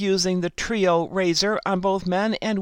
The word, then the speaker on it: Treo: But in this article, Latif continued by saying, more recently, we have designed a Gillette Trio razor, it's spelled T-R-E-O,